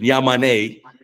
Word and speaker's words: Yamane: Yeah, I think one of the challenges with Yamane, or the correct pronunciation, Yamane,